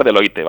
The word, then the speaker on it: Deloitte: No sé si lo digo bien, porque lo tengo que decir en francés y yo tengo un francés tan malo. – Pues bueno, al fin, se hace llamar Deloitte, vaya. – Ah, vale. O sea, sí que es verdad, es un apellido francés y sería quizá la pronunciación correcta, pero vamos, Deloitte. – Ah, vale. Y yo aquí haciéndome el fino y pronunciándolo en francés.